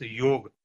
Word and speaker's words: yoga: So the word yoga itself, yoga in English is it's pronounced as yoga, but in our Indian culture, mm-hmm. – it is called as yoga.